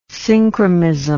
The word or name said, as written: synchromism